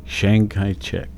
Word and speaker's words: Chiang Kai-shek: Chiang Kai, K-A-I hyphen S-H-E-K. Chiang Kai-shek.